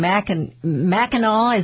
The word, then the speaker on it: Mackinac: Now, somebody on Facebook just told me that in Mackinac, is that how they pronounce it, Island in Michigan? – Yes.